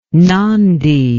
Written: nandi

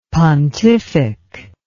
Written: pontific

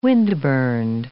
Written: windburned

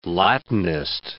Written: Latinist

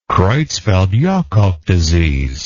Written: creutzfeldt jakob disease

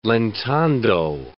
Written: lentando

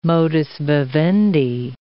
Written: modus vivendi

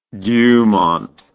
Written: dumont

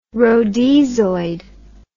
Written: rhodesoid

